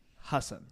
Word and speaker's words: Hasan: But Ellen, my name's actually pronounced Hassan.